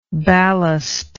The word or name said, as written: ballastic